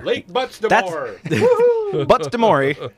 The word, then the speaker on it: Buttes des Morts: I'm going to say Lake Buttes des Morts. – That's actually pretty close. The S is silent, and it's almost like it's all one word. It's Butamore.